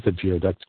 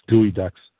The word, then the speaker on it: geoduck: How about some geoducks, gooey ducks. I know how to pronounce it. Why did I say it wrong? Because it looks like geoducks.